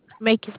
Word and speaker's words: Macon: 3931 River Place Drive in Macomb, Georgia. Macon, Georgia.